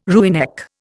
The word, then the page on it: rooinek: Indian